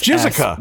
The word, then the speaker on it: Jyssyka: J-Y-S-S-Y-K-A. – No, I'm not trying to be gross. Say, now what? – The pronunciation is... Yeah. – J-Y-S-S-Y-K-A. – Jessica. Why would you do that? – I don't know.